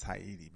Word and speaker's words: Ayiti: Haiti is pronounced Haiti, y'all. You know, if you talk to somebody from Haiti. A-Y-I-T-I. Haiti.